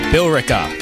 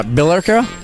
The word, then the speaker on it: Billerica: It's Bill uh, Bill Erica. Bear Bill. What? How do you pronounce that? Bill Rick. There's an E in there. Billerica. Billerica, maybe. Billerica. Oh, that makes sense. Just leave out the E.